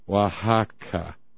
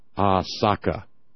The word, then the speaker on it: Oaxaca: So Huaxacac, because they couldn't pronounce the sh diphthong, became Oaxaca, and they just dropped the last k sound at the end.